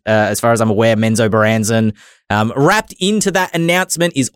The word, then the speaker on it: Menzoberranzan: I'm going to challenge myself to pronounce this. I heard it pronounced like three times. Menzo Baranzen? – Yeah. – You done did it. That's how you pronounce it.